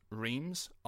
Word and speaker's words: Reims: All French kings were crowned in, and our apologies for this, Reims, or as it's pronounced to us, Reims, R-E-I-M-S.